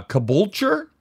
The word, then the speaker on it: Caboolture: I'm afraid to pronounce the town you were actually raised in, but Caboolture? – Yeah, spot on.